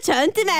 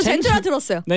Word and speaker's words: 젠투: 네, 전투 펭귄이라는 종이 있는데 이 펭귄들은 마음에 드는 암컷이 있으면 아 젠투 펭귄 전투 싸우자. – 저 젠투 펭귄 알죠? – 아, 제가 질문이 또 나오셨습니다. 네. 젠투의 전투 말고 모르셨어요? – 아, 저 젠투인 거 압니다.